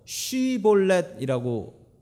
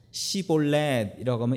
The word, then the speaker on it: 쉬볼렛: 잡아 죽이는데 에브라임 사람들이 똑같이 생겼기 때문에 이게 에브라임인지 아닌지 알 수가 없어서 길라 사람들이 어떻게 했냐면 히브리 말로 쉬볼렛이라고 발음을 해보라는 거예요. 그런데 에브라임 사람들은 이 쉬 자 발음을 못하고 시라고 한다는 거죠. 시볼렛이라고 하면 이리 와라고 해서 때려 죽였다라는 얘기입니다.